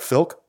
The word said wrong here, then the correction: Flick: I'm pronouncing that right. – Flick. Yeah. – Flick. Mm-hmm. Okay. all right. We got that sorted out.